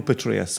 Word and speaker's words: Petraeus: Petraeus, General Petraeus.